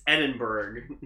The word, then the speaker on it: Edinburgh: It is an all-in-one suit using the fabric of Harris of Edinburgh, founded in 1863. – Edinburgh. – Loved by royals and nobles in Europe as well as many VIPs. – It's not Eden, it's Edinburgh. Eden spelled E-D-E.